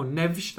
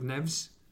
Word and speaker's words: Neves: Is Neves that good, or Neves that good, as James Fielden and other Portuguese media now pronounce it? So I think we're with Neves. Is Neves that good, or Wolves that bad?